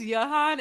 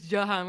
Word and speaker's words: Jahan: He says Jahan. It is Jahan.